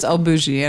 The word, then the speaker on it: El Bulli: El Bulli, that's how you say it. – I thought it was El Bulli, and I was like, of course.